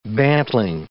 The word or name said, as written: bantling